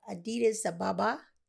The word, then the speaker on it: Addis Ababa: Just coming back home from the motherland, had an opportunity to visit Africa, Ethiopia, and uh, the city of Addis Ababa.